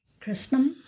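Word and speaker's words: Cristom: This is the Christom. Did I pronounce that right? – Christom? Yeah, it is.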